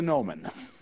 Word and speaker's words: gnomon: G-N-O-M-O-N. Does anybody here know what the English word G-N-O-M-O-N means? It's pronounced gnomon.